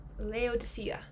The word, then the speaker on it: Laodicea: Laodicea. – Oh, that's nice, isn't it? – Yeah. Laodicea.